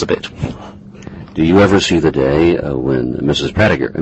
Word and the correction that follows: Pargiter: No, not Pratiger. Pargiter.